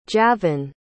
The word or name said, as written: javon